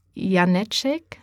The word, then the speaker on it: Janecek: Und beim Zukunftsforum, jetzt musst du mir nochmal helfen, ist zu Gast Dieter Janecek. – Die Betonung liegt auf der ersten Silbe.